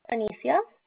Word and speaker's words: Anicia: Anicia. – Can you spell that for me? – It is A-N-I-C-I-A.